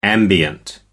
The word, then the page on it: ambient: American English